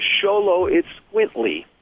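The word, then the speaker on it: Xoloitzcuintli: So, last, and certainly not least, the only dog breed to start with an X is the Sholo Itzcuintly, which, if you're a coward, you can call it the Sholo.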